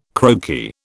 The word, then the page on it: croaky: British English